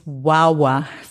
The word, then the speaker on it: Wawa: Also, das ist das Wawa Festival. Wawa phonetisch ausgesprochen, äh, wird auch phonetisch geschrieben.